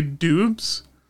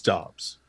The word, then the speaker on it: Dobbs: I would say Dubs. The commentators say Dobbs. – Yeah, and it is Dobbs.